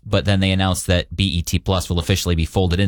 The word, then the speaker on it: BET: Paramount is shutting down the Bet Plus streaming service, or BET. – Oh, yeah. I have heard of that streaming service. – I think I've heard of it. Am I saying it right? – BET. – It's not Bet.